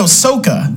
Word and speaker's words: Sokka: Ahsoka, was that right? – The what even character are you going for? Ahsoka's from Star Wars. – No, Sokka.